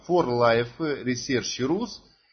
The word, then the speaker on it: For Life Research: Если правильно говорить, не так, как даже в слайде 4 Life, а For Life Research Rus, которая зарегистрирована в городе Москва, в столице нашей родины.